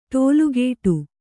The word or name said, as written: ಟೋಲುಗೇಟು